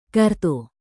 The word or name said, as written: kartu